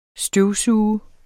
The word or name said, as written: støvsuger